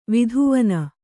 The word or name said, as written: ವಿದ್ವಾನು